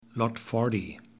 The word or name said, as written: Lot Fourty